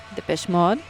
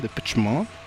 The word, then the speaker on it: Depeche Mode: ¿O cómo se dice? – Depeche Mode. – Ajá, Depeche Mode.